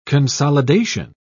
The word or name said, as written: consolidation